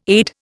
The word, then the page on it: aide: Indian